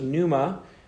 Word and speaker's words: pneuma: We're going to go to the New Testament, and I'm going to introduce you to the New Testament word, which is pneuma. And as the Greeks do sometimes, it's spelled with starts with the Greek letter P, which is silent in this case. So pneuma,